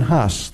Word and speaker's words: Hus: John Huss would have had his name pronounced Hus in his day, and Hus was the Czech word for goose.